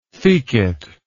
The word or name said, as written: thecate